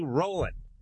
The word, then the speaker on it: Rowland: And until then, we have Kristen Cloke and Rodney Rowland.